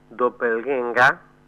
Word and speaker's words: Doppelgänger: Es una criatura que tiene un nombre extraño que se pronuncia Doppelgänger, pero se escribe Doppelganger.